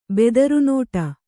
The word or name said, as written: ಬೆದರುನೋಟ